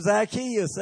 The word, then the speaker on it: Zacchaeus: I finally found out how to pronounce the man's name, Zacchaeus.